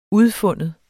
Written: udfinde

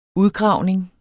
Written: udgravning

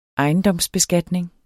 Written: installationsprogram

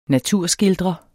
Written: naturskildrer

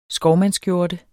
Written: skovmandsskjorte

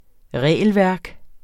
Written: regelværk

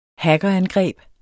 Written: hackerangreb

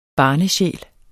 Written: barnesjæl

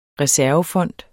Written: reservefond

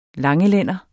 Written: langelænder

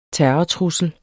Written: terrortrussel

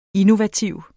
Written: INNOVATIVE

